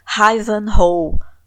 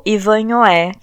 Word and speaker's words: Ivanhoé: Ivanhoe ou Ivanhoé, que é como se escreve.